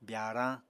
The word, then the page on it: byaarañ: apatani